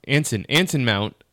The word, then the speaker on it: Anson Mount: I mean, I know we haven't gotten there yet, but a little bit of news that did come out this week is Annis Mount. Is that how you say his name? Anson Mount and Rebecca Romaine,